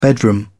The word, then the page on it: bedroom: British English